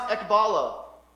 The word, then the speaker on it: ekbalo: That phrase highlighted in yellow, a way out, is actually just one word in Greek and it's pronounced ekbalo. And get this,